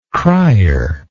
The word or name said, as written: crier